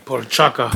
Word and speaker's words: Procházka: Jiří Procházka.